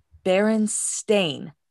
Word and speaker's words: Berenstain: Or E-I-N. I don't know. – It is spelled B-E-R-E-N-S-T-A-I-N. Bernstein.